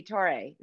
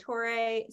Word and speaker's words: Torre: Do you pronounce the e Torre? Is that how you say it? – I say it Tor. Yeah, I say it Torre. Sometimes I say Tori, like Joe Torre. I've I've gotten everything. When I when I went to an Italian signing, they pronounced it probably the correct way, which I don't even remember.